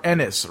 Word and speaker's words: Ennis: By the way, Luke's got the pronunciation correct for Ennis, rhymes with tennis.